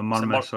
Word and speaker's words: Marymass: Just bad pronunciation from everyone from this part of the country. Murmus. I don't really know it. If you read it in words, it's spelled Marymass. So it can't be anything else but Mary Mass. But for whatever reason, it's not. But yeah, certainly I see a convincing win for Irvine Vicks.